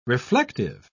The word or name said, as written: reflective